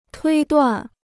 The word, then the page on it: 推断: tuī duàn